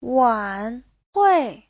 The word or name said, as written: wan3hui4